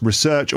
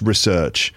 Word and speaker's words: research: The noun can be pronounced research or research. There's two ways to pronounce the noun. The verb is always pronounced research, to research something.